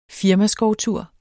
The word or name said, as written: firmaskovtur